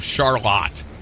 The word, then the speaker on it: Charlotte: You also share your birthday with cool Michiganders like Dustin Klaus of Charlotte, which is how that's pronounced.